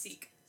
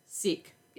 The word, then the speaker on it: Sikh: How is it pronounced? – Sikh. – Sikh. – Yep.